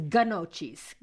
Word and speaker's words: gnocchi: I thought it was gnocchis. – Gnocchi. – See? – Gnocchi. – It's a gnocchi? – Yeah. G-N-O-C-C-I. Gnocchi. – Starts with a G, which totally throws you off.